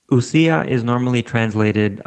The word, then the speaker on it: ousia: So those terms are in Greek, and correct me if my pronunciation is not what you prefer, but I say ousia and hypostases.